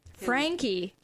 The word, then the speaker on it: Franke: Frankie, that's how it's pronounced.